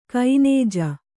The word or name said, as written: ಕೊಞ್ಞೆ